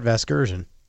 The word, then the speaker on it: Vasgersian: Vergasian? How do you say the guy's last name? Vergasian? Isn't his last name Vergasian? – You know who that is. – That would be my pal Matt Vasgersian.